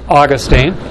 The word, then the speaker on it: Augustine: Augustine of Hippo, known to us as Saint Augustine, or sometimes it's pronounced Saint Augustine, grew up in North Africa and was raised by a godly mother, Monica.